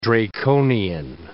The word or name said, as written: draconian